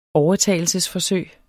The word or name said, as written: overtagelse